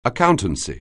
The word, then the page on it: accountancy: anglais